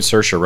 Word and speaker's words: Saoirse: I enjoyed Saoirse Ronan in it not Saoirse Ronan. Yeah. – It's yeah, yeah, yeah, yeah. Okay, got her name right.